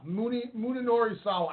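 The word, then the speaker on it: Munenori Sawa: Ring Kampf defeated Daisuke Sekimoto and Muniwara Sawa. – Muninori Sawa.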